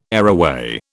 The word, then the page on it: areaway: British English